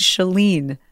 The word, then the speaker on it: Shalene: It's Shalene. It rhymes with Celine.